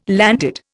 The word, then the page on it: landed: Indian